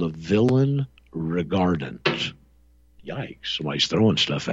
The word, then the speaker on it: villein: And it's spelled V-I-L-L-E-I-N, like villain, but it's pronounced English villain.